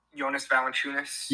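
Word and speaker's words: Valančiūnas: Jonas Valanciunas.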